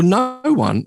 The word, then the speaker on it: Noone: Uh, well, no one is actually. Um, well, I'm actually giving you his name by saying no one, but it's not pronounced no one. Oh, yeah, okay. Peter Noone, Herman's Hermits.